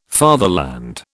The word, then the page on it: fatherland: British English